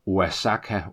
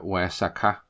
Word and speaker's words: Oaxaca: Og her skal jeg måske lige forklare lidt spansk, at Oaxaca staves O-A-X-A-C-A, og ikke Oaxaca, Oaxaca. Som nogen måske vil udtale det. X'et det bliver stumt derinde i, og O'et bliver nærmest til W, så det bliver til Oaxaca.